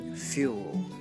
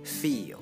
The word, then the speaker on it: fuel: 这个单词的发音 fuel 和感觉那个单词 feel 是不一样的，注意听音上的辨析。我再念一遍 fuel feel。